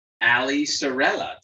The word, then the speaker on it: Saarela: I'm very excited to be joined by Allie Saarela. Did I say it right? – Yeah, you said it right. It's Finnish, which is a little different spelling with all those vowels, but it's Saarela.